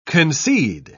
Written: concede

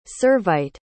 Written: Servite

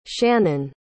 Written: shannon

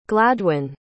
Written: gladwyn

